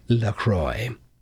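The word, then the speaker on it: LaCroix: La Croix. Sparkling water gets its fancy French name straight from Wisconsin. It's a cross between La Crosse and St. Croix River in western Wisconsin, officially pronounced La Croix.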